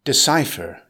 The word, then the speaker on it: decipher: Decipher. It's a verb with three syllables. Stress the second syllable. It's pronounced decipher. Here's an example.